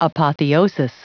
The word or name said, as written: apotheosis